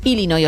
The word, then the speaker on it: Illinois: Pero es Illinois, o sea, si tú lo pronuncias bien y tienes buena dicción, es Illinois.